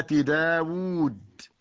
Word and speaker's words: داود: أيضا مما ينطق ولا يكتب الواو في كلمة داود، تكتب هكذا بالرسم العروضي داود، ومثلها أيضا طاووس.